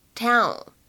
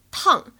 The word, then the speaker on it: town: Well, I also have the best attorney in town, so do your worst. 在这句话朗读过程当中呢，其实没有特别多需要注意的发音技巧，就是注意一下“ 城镇”这个单词，它的发音呢，千万不要读成汉语拼音当中的这个 town。这个是不对的，因为当中的话呢是一个双元音的一个 l，后面呢加上一个前鼻音，所以呢应该是 town town。